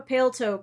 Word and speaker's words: paletot: Like, so if you look up a pale toe coat, which it's spelled P-A-L-E-T-O-T, it's French, it's French, so the last T is silent.